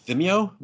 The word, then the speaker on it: Vimeo: Was that Vimeo? V-I-M-E-O? I don't know how to pronounce that. – Yeah, this one's on Vimeo.